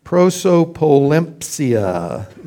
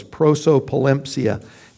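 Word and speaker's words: Prosopolempsia (προσωποληψία): Partiality, the Greek term here, frankly, is long and hard to pronounce. Prosopolempsia is pretty close. Prosopolempsia.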